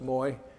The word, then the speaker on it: Mawi: M-A-W-I is pronounced Moi.